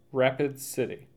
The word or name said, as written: Rapids City